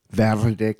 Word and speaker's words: Verde claro: Yes, so this is how you say it. Verde claro.